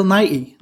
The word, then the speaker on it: Nighy: I didn't realize if there was a T in there. So Bill Nighy.